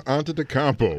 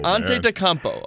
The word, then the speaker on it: Antetokounmpo: It's right, Giannis, good grief, Antetokounmpo. – Antetokounmpo.